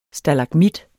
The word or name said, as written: STALAGMIT